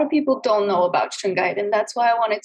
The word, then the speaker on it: shungite: And you wanted to talk about, I'm going to say this wrong, is it shungite? – Shungite.